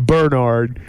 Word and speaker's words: Bernard: They always put the emphasis on that first syllable. They call him Bernard.